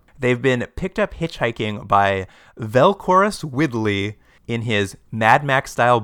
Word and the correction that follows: Velcrows: His name is actually Velcros. It's spelled V-E-L-C-R-O-W-S.